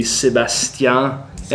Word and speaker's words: Sébastien: Or how would you say that in French? – The name of the artist would be Sébastien, and probably Total would be the name of the record.